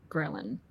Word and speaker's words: ghrelin: And ghrelin sounds like a gremlin, and it's spelled G-H-R-E-L-I-N.